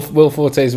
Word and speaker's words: Forte: Will Forte's Wikipedia page, the pronunciation thing says Forte, so I was right.